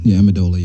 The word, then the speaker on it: Amendola: So yeah, after I pick out what I want, like y'all know my favorite is the Amendola. Is it Amendola? Did I say it right? The dessert cigar that I love. – Yeah, Amendola. Yeah.